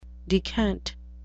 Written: decant

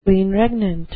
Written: queen regnant

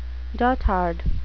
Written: dotard

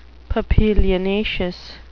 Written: papilionaceous

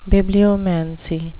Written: bibliomancy

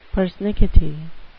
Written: persnickety

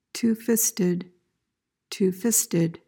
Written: two-fisted

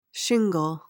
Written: shingle

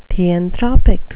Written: theanthropic

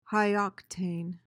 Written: high-octane

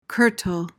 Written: curtal

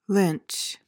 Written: lynch

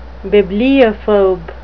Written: bibliophobe

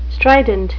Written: strident

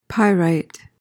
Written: pyrite

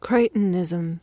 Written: crichtonism

